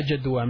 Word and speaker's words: جدوى: لا جدوى من الكلام. لا جدوى من الكلام. There is no use talking. لا جدوى. لام ألف جيم دال واو أنياء مقصورة which is pronounced as ألف من الكلام.